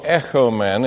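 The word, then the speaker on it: echomen (ἔχωμεν): If it's short O, echomen, it means "we have." If it's the long O, echomen, it's "let us have."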